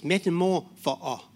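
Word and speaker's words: metamorpho (μεταμορφόω): So that again, it's metamorpho. That's how you pronounce it.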